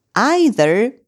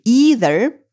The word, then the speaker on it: either: Так вот, either или either, существует два допустимых варианта произношения, используют как тоже в отрицательных предложениях, тоже нет.